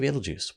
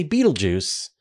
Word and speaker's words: Betelgeuse: So I'm going to say Betelgeuse, which is perfectly acceptable. Like if you go and look up pronunciation guides for Betelgeuse, that is one perfectly acceptable way to say the word.